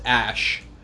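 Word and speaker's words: Æ: He said it's an X and then the AE thing is pronounced Ash.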